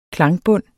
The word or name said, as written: klangbund